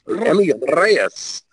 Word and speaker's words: Reyes: And you have to roll your R when you say that, like ruffles have ridges. Emmy Reyes.